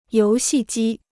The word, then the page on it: 游戏机: yóu xì jī